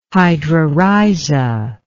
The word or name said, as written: hydrorhiza